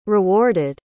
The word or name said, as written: rewarded